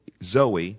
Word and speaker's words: zoe: It's Z-O, zoo, zoe, not zoe. Or yeah, zoe, zoe, long E.